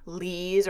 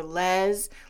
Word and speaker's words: LES: Now, I know that it is easy to read that and think that it's pronounced lease or les, like a lease statement, a les statement. Nope, it's just pronounced LES, and that stands for leave and earning statement.